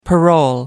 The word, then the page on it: parole: American English